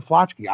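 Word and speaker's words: flaczki: I have an Polish soup called flotchki. Is that how you pronounce it correctly? – Flotchki? Oh, yeah. Flotchki. That means intestine.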